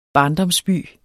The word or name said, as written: barndomsby